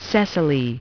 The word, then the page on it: cecily: British English